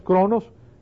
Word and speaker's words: chronos: La primera es cronos y se escribe C-H-R-O-N-O-S, cronos, cronómetro, de ahí viene la palabra cronómetro.